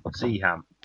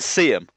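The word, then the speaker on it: Seaham: Spelled C with ham on the end. – We've been through this. How do you pronounce Birmingham? How do you pronounce Nottingham? – Nottingham. – Yeah, so it's Siam.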